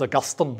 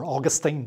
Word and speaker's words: Augustine: His name was Augustine, or Augustine, depending how you pronounce it.